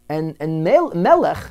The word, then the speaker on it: Melech: And Melech, his name was Melech. They called him Melech. It's a, you know, Lithuanian or White Russian pronunciation.